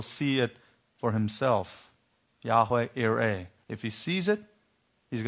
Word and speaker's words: Yireh: Genesis 22:14, Abraham called the name of that place, the Lord will provide, Yahweh Yireh. Or we would say Jehovah Jireh with our English J pronunciation.